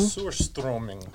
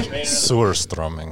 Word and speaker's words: Surströmming: is it pronounced Surströmming? – Surströmming. – Like, say that again, in the mic. – Surströmming.